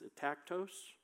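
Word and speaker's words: atactos (ἄτακτος): This word disorderly in the Greek is atactos. Hopefully I said that right. Sounds good to me.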